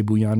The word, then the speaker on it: Bouillon: So it's Bouillon, as in Bouillon Cube.